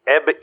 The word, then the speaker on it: Ebn: Hi, I'm Eb Emus. – Eb? – Eb. – Eb Emus. – Yes, Eb, E-B-N. The N is silent.